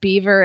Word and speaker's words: Belvoir: Apparently it's a hunting term, and most people pronounce it beaver. And if you're fox hunting, everybody will make fun of you for pronouncing it.